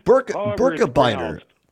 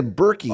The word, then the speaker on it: Birkebeiner: Hey, by the way, I wanted to ask you, is that, how do you pronounce it, the Birkebeiner, the Birkebeiner? – Birkebeiner, yeah. I assume it's Swedish or Norwegian. Pardon me, Norwegian. – Okay. Yeah. Oh, okay. – So yeah, Birkebeiner. Some people say Berkey. Oh. I saw it's Birkebeiner, but I think most people here pronounce it Birkebeiner.